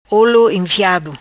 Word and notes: Olo Enfiado: patua

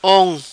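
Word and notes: Ong: patua

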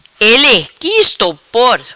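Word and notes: Ele qui estopor: Patua

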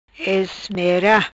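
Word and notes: Ismera: Patuá

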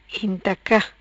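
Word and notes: Intaca: patua

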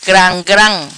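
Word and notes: Gram Gram: patua

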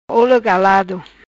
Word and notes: Olo Galado: patua

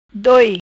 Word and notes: Doi: Patua

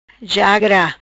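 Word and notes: Jagra: patua

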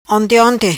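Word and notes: Onte Onte: patua

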